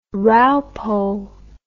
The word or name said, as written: raupo